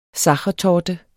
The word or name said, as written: sachertorte